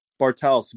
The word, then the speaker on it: Bartels: Last name Bartels. – Peter, say your last name again. – Bartels, B-A-R-T-E-L-S-E.